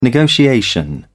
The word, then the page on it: negotiation: British English